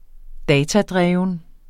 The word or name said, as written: datadreven